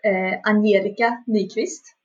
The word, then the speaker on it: Angelica Nyqvist: Okay, well, my name is Angelica Nyqvist.